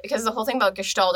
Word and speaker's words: Gestalt: Gestalt. – Yeah, I was like, I want to say the S is like a sh.